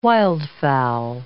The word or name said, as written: wildfowl